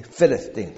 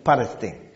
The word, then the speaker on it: Palestine: 拉丁文是在将近两千年前罗马人统治整个今天所谓的以色列了这一带，包括黎巴嫩这一带的时候，罗马人称这块地叫做 Palestine，其实就是腓力士人之地。那照希腊语念的话是 Philistine，但是拉丁文念的时候不是念成 F 的音 Philistine，它是 Palestine，所以变成巴勒斯坦。